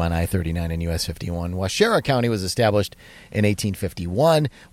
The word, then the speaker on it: Waushara: The cabin is presented to you by the Wisconsin Counties Association, and this week we're featuring Washara County. I'm pronouncing that right, Josh? – It's Washara. – Washara.